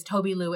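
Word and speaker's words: Tobi Lou: His name is Toby Lou, and it's spelled T-O-B as in boy, I-L-O-U.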